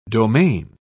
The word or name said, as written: domain